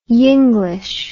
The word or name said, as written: yenglish